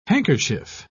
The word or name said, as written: handkerchief